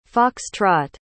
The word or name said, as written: fox-trot